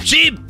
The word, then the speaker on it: sheep: sheep. No, sheep, sheep. – Sheep lleva doble E. S-H-E-E-P. Sheep.